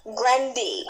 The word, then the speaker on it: Grande: You know, she said we pronounced her name wrong. She said her name is Grandy. – Ariana Grande.